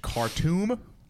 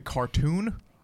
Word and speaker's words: Khartoum: Well, when you when you told me to watch Khartoum, you both pronounced it cartoon.